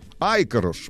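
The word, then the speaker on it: Ikarus: Хотя вот есть версия, что на венгерском языке название так. этих автобусов угу. читается как «Айкаруш». Айкаруш, вот, на, с ударением на первый слог.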